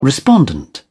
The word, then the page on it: respondent: British English